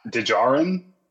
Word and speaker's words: Djarin: Djarin.